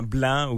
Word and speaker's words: blanc: C'est comme le C, c'est comme le même C que dans blanc ou dans ban. On le prononce pas.